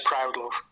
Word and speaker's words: Proudlove: Is it pronounced Proudlove or Proudlove? – Yeah, it's Proudlove.